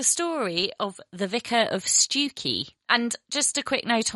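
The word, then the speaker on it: Stiffkey: So actually, Stewkey is written Stifkey.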